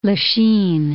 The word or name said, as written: Lachine